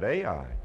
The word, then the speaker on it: Ai: just two letters in the name, Ai. That's the way you spell it. That's the way you pronounce it, Ai.